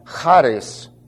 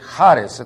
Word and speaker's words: charis: If you Greek students want to write the word down, it's from charis, not charis. Okay? Spell it with an H better than a CH. It's charis. It's a light guttural. H-A-R-I-S.